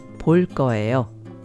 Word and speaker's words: 볼 거예요: Pronounced 볼 거예요. 볼 거예요.